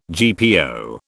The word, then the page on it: GPO: British English